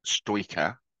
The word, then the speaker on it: Stoica: I think Andrea was Stoica. Yeah, it was the right pronunciation.